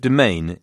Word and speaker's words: demesne: But domain is spelled D-E-M-E-S-N-E. It's a French word, coming from the word for mansion in Old French, meunie. So, de meunie, of the house, of the household. Clearly, it's pronounced totally incorrectly for any proper Anglo-Saxon, but the S is silent as a result of an ancient device to show that the vowel was long.